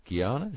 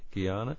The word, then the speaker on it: Guyana: Uh, Guyana is that how you pronounce it? Yes. Yeah, Guyana, and that's that's a little bit later on.